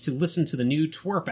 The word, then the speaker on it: TWRP: So Twerp, it's spelled TWRP, and that stands for Tupperware Remix Party.